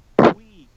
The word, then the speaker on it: Puig: a pig licks baseball bat. He's not the first to do so. – It's pronounced Puig.